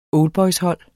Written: oldboyshold

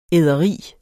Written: æderi

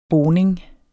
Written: boning

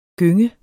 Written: gynge

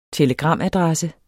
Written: telegramadresse

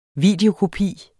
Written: videokopi

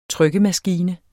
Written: trykkemaskine

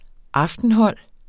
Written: aftenhold

